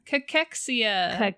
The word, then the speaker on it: cachexia: That is not how it's spelled. – Nope. – This would be one of those where I would need it spelled phonetically because you would not, well, you might. I did not look at that word and go, sure, that says cachexia. Mm-hmm, definitely. – It looks like cachexia. – C-A-C-H-E-X-I-A.